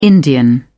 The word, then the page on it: Indian: British English